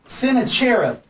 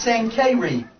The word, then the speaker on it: Sennacherib: Now, if you look in 2 Chronicles chapter 32, there's this guy whose name, looks like Sennacherib, right? And that's how I always pronounce it, but I went and kind of did this study when I was preparing for the class, and that's not how you pronounce it. His name is pronounced Sennacherib. Think Sennacherib, and the emphasis is on the last syllable.